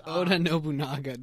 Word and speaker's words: Oda Nobunaga: His team is Oda Naga from Drifters. – Oda Nobunaga. Did you just pronounce Oda Nobunaga wrong? – I think I did.